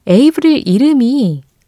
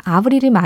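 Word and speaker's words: Avril: 아니, 그랬더니 위은경 님이 에이브릴 이름이 에이프릴에서 온 걸로 알고 있어요. 그리고 발음은 사실 에이브릴이 아니라 아브릴이 맞아요.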